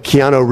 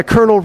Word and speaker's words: Keanu: You mispronounce names like Keanu Reeves. You say, oh yeah, Colonel Reeves, right?